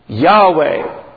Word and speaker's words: Yahweh: It's really a reflection of the highest name of God, the glorious, most resplendent name of God, which is usually now pronounced Yahweh, Y-A-H-W-E-H,